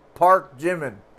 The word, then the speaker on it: Park Jimin: Park Jimin.